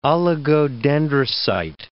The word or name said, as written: oligodendrocyte